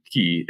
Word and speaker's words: Caye: So A M B E R G R I S, and then Key is C A Y E.